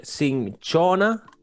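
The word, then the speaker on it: Cinchona: Se llama, verga, Sin, Shin, no, no, se debería pronunciar sinchona officinalis.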